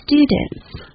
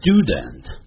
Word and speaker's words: student: They are my students. – Student. 是单数学生，按照我们学英式的英语可以念成 student，但在美式英语啊念成 student， student。那有时候念快的时候用鼻音出来 student， student，嗯的音啊。